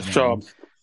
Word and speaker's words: Shams: And so whether Shams, I don't even know how you pronounce it. – Yeah, Shams.